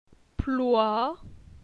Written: Ploi

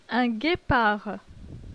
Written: Un guepard

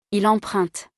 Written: il emprunte

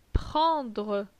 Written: Prendre

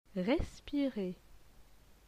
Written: respirer

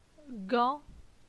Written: Gan

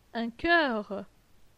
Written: Un choeur

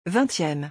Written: 20eme

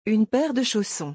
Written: une paire de chaussons

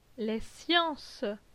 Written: Les sciences

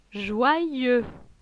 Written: Joyeux

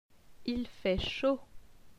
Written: Il fait chaud

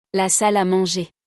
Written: La salle a manger

